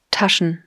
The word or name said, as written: Taschen